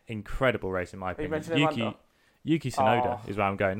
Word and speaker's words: Yuki Tsunoda: Yuki Tsunoda, I love him.